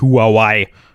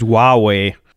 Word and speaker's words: Huawei: Weil das heißt gar nicht Huawei, das heißt Huawei.